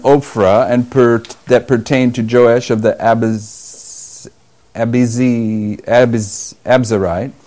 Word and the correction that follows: Abiezrite: Somebody want to pronounce that for me? I'm in verse 11. Joash the Abiezrite. Abiezrite.